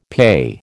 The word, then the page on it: PE: American English